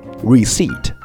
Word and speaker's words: receipt: 我给大家举几个例子，比如说 receipt， R-E-C-E-I-P-T， receipt， OK，这个单词啊意思是名词，收据，发票。但是大家注意啊， receipt 这个词里面倒数第二个字母 p 是不发音的，所以如果你读成了 receipt，那么听力里面出现的时候你听不懂的概率就非常非常大。